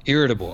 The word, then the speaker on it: irritable: And are you iterable? Is that how you pronounce that word? – Irritable.